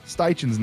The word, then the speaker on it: Steichen: Their offense is the real deal, led by head coach Nick Sirianni and offensive coordinator Shane Spikeman. – Shane Spikeman. Billy, there's no P in Shane Steichen's name.